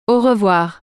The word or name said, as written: au revoir